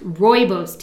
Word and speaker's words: Rooibos: Rooibos. That's how it's pronounced. Rooibos tea. It's spelled R O O I B O S and it's pronounced Rooibos tea.